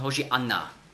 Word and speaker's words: Hosianna: Und rette doch heißt Hosianna, also hebräisch ausgesprochen Hoshianna. Es ist bei uns in der Bibel Hosianna im Neuen Testament, weil es im Griechischen gibt es ja den Sch-Laut nicht. Den muss man eben ersetzen durch S.